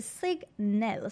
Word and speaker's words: Scignel: Yes, that is pronounced as Signel. So S-C-I-G-N-E-L.